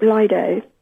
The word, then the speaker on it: Lido: And the I is pronounced an I with a colon after it, as in the word me. – I. – Therefore, the correct pronunciation is Lido.